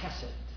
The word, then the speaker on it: chesed: It is the word chesed. If you haven't had your coffee, it's H-E-S-E-D. Chesed.